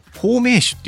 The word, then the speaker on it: 保命酒: 保命酒っていうのはね、命を保つっていう、保険の方に、命っていう字を書いて保命酒と読むんですね、